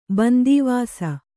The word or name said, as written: ಬಂದೀವಾಸ